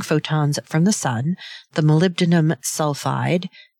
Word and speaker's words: molybdenum: molybdenum sulfide, copper, and iron oxide. I always used to pronounce molybdenum molybendum. So I always still have a little bit of trouble saying that.